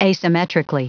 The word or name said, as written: asymmetrically